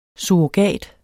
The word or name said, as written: surrogat